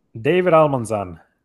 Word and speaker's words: David Almanzan: David Almanzan.